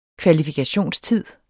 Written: kvalifikationstid

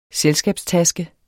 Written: selskabstaske

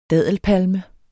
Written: daddelpalme

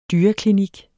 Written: dyreklinik

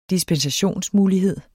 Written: dispensationsmulighed

